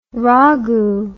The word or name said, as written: ragu